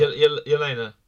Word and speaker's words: Yelena: I think the other obvious one is I can't pronounce her name, Yelena, Black Widow's sister. – Yelena.